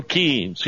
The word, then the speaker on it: Keynes: K-E-Y, key. I know he liked to say, it rhymes with brains, but it doesn't. Keynes didn't have a brain.